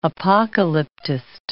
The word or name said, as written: apocalyptist